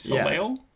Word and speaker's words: Soleil: Soleil? – Yeah, S-O-L-E-I-L.